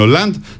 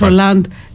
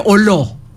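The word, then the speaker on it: Hollande: Τώρα που είπες Ολάντ, θα ήθελα να απευθυνθώ στους συναδέλφους που δεν είναι γαλλοματίες ότι δεν τον λένε Ολό. – Εντάξει, δεν τον λένε Ολό. – Κάποιοι τον προφέρουν έτσι. – Όχι, δεν είναι Ολό.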